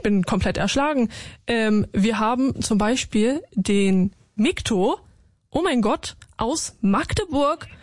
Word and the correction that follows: Mitko: Ich heiße Mitko, M-I-T-K-O. – Oh mein Gott, Mitko. Ja. So ist richtig.